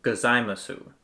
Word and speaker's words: gozaimasu: And that last word is spelled G-O-Z-A-I-M-A-S-U. So we started pronouncing it, you know, gozaimasu. But in everyday usage, it sounds totally different. And actually, you'd often hear the full phrase come off a little bit something like arigato gozaimasu, which is the whole arigato and gozaimasu all in just this one sort of delicious roller coaster of a phrase.